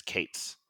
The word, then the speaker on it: k8s: So my daughter's name is Kate, and the pronunciation of K8S is Kates.